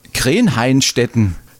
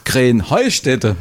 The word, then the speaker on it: Krähenheustätte: Ja, ihr saget immer Krähenhainstetten. Und dabei heißt es doch Krähenheustätte, oder wie? – Krähenheustätte. – Ja, genau, und das ist die Betonung auf dem Heu.